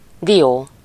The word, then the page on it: nut: /nʌt/